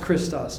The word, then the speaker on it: Christos: Yes, it's pronounced Christos. Thank you.